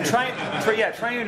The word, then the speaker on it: Triune: Yeah, and Triune is just like the word,